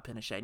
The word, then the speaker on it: Pinochet: Pinochet. We had this whole conversation where you were like, someone's told me you just pronounced the T.